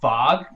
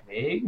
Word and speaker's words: vague: And like, I used to have a friend who pronounced Vague Vog because he didn't know any better.